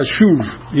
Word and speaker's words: shuv: Tiene la terminación en el hebreo, es con una B grande, pero se pronuncia shuv y es regresar.